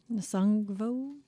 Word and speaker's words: Nusungvo: NUSUNGVO. NUSUNGVO. – Nusungvo? – Nusungvo.